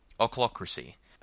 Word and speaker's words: ochlocracy: So first of all, ochlocracy. I may pronounce that wrong. It's spelled O-C-H-L-O, crassy.